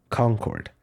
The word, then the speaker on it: Concorde: And that's exactly why the plane was named what it is, Concorde. It's pronounced the same in French and English, and it means agreement, harmony, or union.